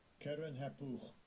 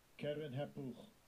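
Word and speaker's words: Keren-happuch: Karen Hapuch. Karen Hapuch.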